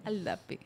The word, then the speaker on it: Aldap: A, a, a, eu sempre erro o teu nome, eu falei certo, Carol? Carol Aldap. – Aldap.